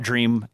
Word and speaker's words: DRIMe: And they call it Dream. It's spelled D-R-I-M-E with a space and then the letter V, but it's pronounced Dream from what I've gathered.